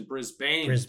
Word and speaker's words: Brisbane: You may know him specifically as an Australian actor who moved from his hometown of Brisbane, as the proper pronunciation, Brisbane.